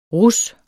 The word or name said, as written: RUSSEN